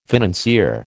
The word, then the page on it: financier: American English